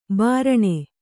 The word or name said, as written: ಬುರಣೆ